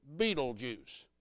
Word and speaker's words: Betelgeuse: Yeah, I said it right. Betelgeuse. Yeah, I know it probably should be spelled Beetlejuice instead of like they did it, but that's the spelling.